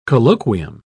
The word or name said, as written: colloquium